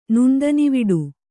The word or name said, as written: ನುಣ್ದನಿವಿಡು